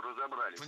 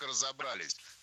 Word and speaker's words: разобрались: Ну, как разобрались? Насколько это возможно? – Разобрались неверно. Вспоминаем про всемирное тяготение части «с» к ударению и произносим правильно «разобрались».